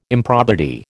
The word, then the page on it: improbity: American English